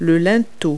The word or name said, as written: lintel